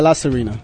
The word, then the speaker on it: La Serena: So if you come here to the Mermaid Bar or La Serena, am I saying it right? – Yeah, La Serena.